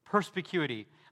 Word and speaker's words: perspicuity: I really do love the doctrine of perspicuity, but I also just plain love that word, perspicuity. I like how it sounds. I like that I know how to pronounce it, and that's how you pronounce it.